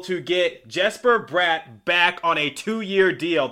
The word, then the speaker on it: Bratt: So, I personally prefer Jesper Bratt, so like bratwurst, like the German sausage.